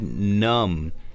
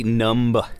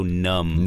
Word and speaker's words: numb: This word numb, kind of like the word dumb. – Dumb. – Yeah. We don't say numb. – Right. The B is silent. – Yeah. The no b sound. Just so numb.